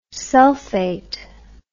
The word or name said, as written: sulphate